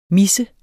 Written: mis

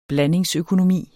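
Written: BLANDINGSØKONOMI

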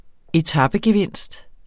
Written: etapegevinst

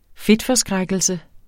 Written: fedtforskrækkelse

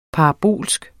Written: parabolsk

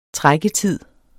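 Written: trækketid

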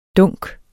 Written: dunken

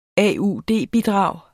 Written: AUD-bidrag

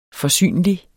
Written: forsynlig

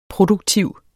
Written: Produktiv

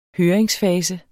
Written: høringsfase

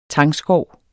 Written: tangskov

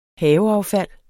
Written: haveaffald

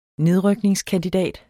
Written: nedrykningskandidat

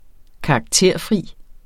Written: karakterfri

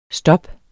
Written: STOPPENES

